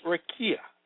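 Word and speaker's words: raqia: The Hebrew word for firmament is rekuia, pronounced rekia, meaning extended surface, expanse, vault of heaven supporting waters above.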